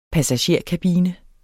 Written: passagerkabine

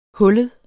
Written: hullet

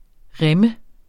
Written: rimme

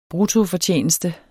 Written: bruttofortjeneste

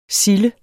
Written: silde

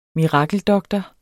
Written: MIRAKELDOKTORERNES

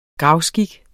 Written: gravskik

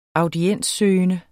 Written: audienssøgende

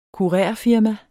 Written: kurerfirma

